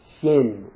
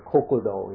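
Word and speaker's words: shin (心): It's pronounced shin or kokudo in Japanese, translated as heart or mind or sometimes soul or true self.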